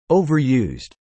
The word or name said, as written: overused